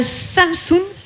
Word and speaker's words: Samsun: tu es née à Samsun, si je le dis bien. Ouais, sur la côte de la mer Noire en Turquie.